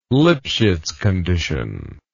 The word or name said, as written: lipschitzcondition